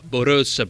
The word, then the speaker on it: Borås: Poi avete sentito, la pronuncia è quella giusta, Borås, perché con le lettere con il tondino sopra nelle lingue scandinave e anche norvegese diventano praticamente una O.